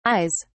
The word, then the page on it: ayes: British English